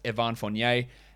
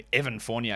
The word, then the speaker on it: Evan Fournier: His name is not Evan. Like, it's not Evan. Like, it's Evan. That's to me how it's pronounced French. It's not Evan Fournier. It's Evan Fournier, right?